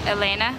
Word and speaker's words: Elena: Elena. – How do you spell it? – E-L-E-N-A.